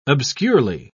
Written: obscurely

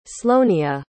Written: sloanea

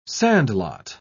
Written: sandlot

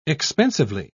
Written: expensively

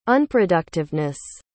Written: unproductiveness

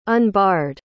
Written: unbarred

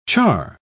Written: char

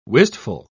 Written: wistful

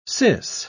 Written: sis

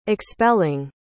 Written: expelling

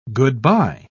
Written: good-bye

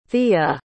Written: theia

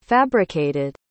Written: fabricated

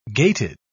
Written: gated

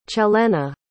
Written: celena

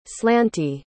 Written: slanty

